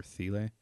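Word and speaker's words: Thile: So, Mr. Garrison Keillor's former show, which became Chris Thiele's, is that how you say it? Or Thiele? Thiele, right? – Yes.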